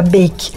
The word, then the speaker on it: Beik: Eu provei outro dia os cookies de uma marca chamada Bake. Se escreve B-E-I-K não é uma marca nova, existe já desde 2013.